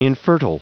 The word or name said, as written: infertile